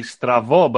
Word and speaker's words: Strabo: And if you pronounce the B in the correct Greek, it would be Stravo, but Stravo means crooked.